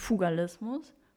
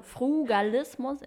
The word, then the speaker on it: Frugalismus: Ja, also ich habe das Thema, ich habe es gerade gesehen, ich habe es falsch ausgesprochen. Das heißt Furgalismus, nicht Fugalismus. Fur? – Fru steht da. – Danke, okay. Ja, Fru, Fru. – also Frugalismus, Entschuldigung.